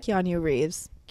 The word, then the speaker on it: Keanu Reeves: Keanu Reeves.